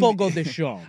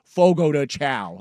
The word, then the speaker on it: Fogo de Chão: It's spelled Fogo de Chão, but yeah. in Portuguese, in, Fogo de Chão. – I was going to say, in white, middle-aged American vernacular, that is Fogo de Chão.